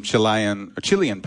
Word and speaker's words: Chile: Well, on that note, I went and saw some Chilean people in the crowd.